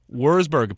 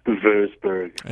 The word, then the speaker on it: Würzburg: Würzburg, apparently. He's 48 years old now. – I believe it's properly pronounced Würzburg. – Yeah, my mistake.